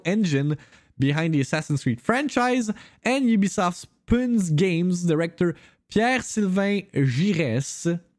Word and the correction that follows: Pune: Looked it up, ça se prononce Pune. Pune.